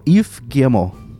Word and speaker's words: Yves Guillemot: E przed odcinkiem sprawdzałem parę razy, żeby tym razem, no, razem poprawnie wymówić, żeby poprawnie wymówić jego imię i nazwisko, bo te francuskie nazwiska są triki i mi się wydaje, że się je wymawia jakoś, a tak naprawdę się je wymawia inaczej. I wymawia się go Yves Guillemot.